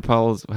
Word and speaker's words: Pulisic: we won 1-0, as they say, in soccer, and we, Christian Pulisic, how do you say his last name? – Pulisic.